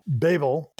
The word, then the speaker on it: Babel: Yeah, I would imagine Babel is spelled B-A-B-B-L-E. – Like what we do every show. You'll be interested to know that the way that it's said in the show, Babel, is the way the English pronunciation is. And the Americans would say it as Babel.